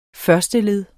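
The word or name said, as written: FØRSTELED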